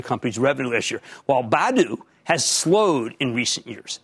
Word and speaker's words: Badoo: Now, this would be an easier call, frankly, if it were just Bumble without the Badoo, which, by the way, is pronounced like Scooby Doo, Badoo baggage.